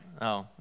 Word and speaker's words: Illinois: Illinois.